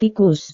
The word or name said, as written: tikus